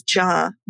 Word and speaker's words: Jha: I feel like we could do an entire episode just on names, but my name is pronounced Shonda, and it's spelled S-A-N-D-H-Y-A, and the last name is Jha, J-H-A. – And just for the record, everyone, I'm Katie, K-A-T-Y.